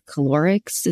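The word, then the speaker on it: cholerics: And then calorics. Is that how you say it? If you've been listening. – Like cholera.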